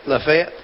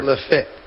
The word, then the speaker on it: Lafayette: That number 52 for, let me make sure I said this right. – You're right. – Lafayette. – Yes, sir. – Lafayette.